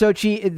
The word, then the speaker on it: Xochitl: Pronounced Xochitl. They put Xochitl in here, but I've never seen it. Everyone that I listen to didn't pronounce an L at the end. So if I'm getting that wrong, I could be getting that wrong, but I am not doing so on purpose. So I apologize, but I was always heard it pronounced as Xochitl.